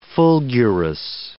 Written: fulgurous

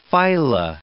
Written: phyla

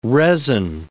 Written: resin

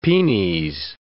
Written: penes

